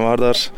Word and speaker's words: Vardar: Hallo, ich heiße Sajan Vardar und ja, ich habe einen YouTube-Kanal, der läuft zu dem Namen Berjan.